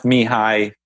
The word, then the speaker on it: Mihaly: I don't know how to spell his name, but his first name is Mihaly. So it's pronounced Mihai.